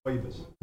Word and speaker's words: Rooibos: The caramel kiss, caramel ribos. Uh, how you that pronounce? right. – That was right. Okay. Ribos. Ribos. Is that ribos? – Rooibos. Rooibos.